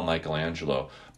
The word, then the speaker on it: Michaelanegelo: if you look at the way that I spell Michelangelo, Michael Lane is fit in there. And that's why I spell it that way. It's M-I-C-H-A-E.